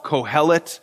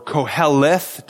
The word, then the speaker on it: Kohelet: He calls himself Kohelet, or Koheleth, depending on you can pronounce it both ways.